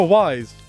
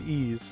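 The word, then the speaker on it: Ys: I mean, to I mean, like a native English speaker, like yeah, it's like oh, wise. No, no, it's actually ease.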